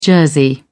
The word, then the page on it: jersey: British English